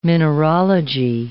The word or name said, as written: mineralogy